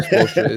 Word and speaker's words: Spoelstra: You said Spoelstra? – Spoelstra. I said Spoelstra. Spoelstra is his name, but whatever. I'm going to keep calling him Spoelstra because his mama name is Spoelstra.